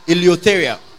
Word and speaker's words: eleutheria: Now, the word freedom comes from the Greek word eleutheria. Eleutheria. It is spelled E-L-E-U-T-H-E-R-I-A. E-L-E-U-T-H-E-R-I-A. E-L-E-U-E-L-E-U. T-H-E-R-I-A.